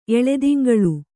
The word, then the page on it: ಎಳೆದಿಂಗಳು: eḷediŋgaḷu